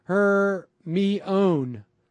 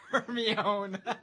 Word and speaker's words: Hermione: No one knew how to say Hermione until the movies. I thought it was like her, me, own.